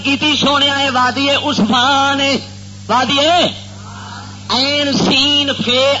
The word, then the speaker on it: عثمان: این سین فے الف نون عثمان بار وزن عثمان یعنی جیمے لفظ عثمان پڑھا اسے طرح عثمان اسے نالے اے سین نالے تلفظ دا صحیح کرنا بھی کسی جگہ تے حوالہ پیش کرو تے غلطی مت کھا جاؤ.